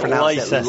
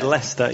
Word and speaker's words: Leicester: You pronounce it Leicester. – Leicester. – If you walk into that city and say Leicester, you'll be laughed at.